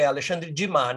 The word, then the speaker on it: Alexandre Dumas: Alexandre Dumas, pronunciei corretamente, Rodrigo é Alexandre Dumas, né? – Uhum. É, uhum.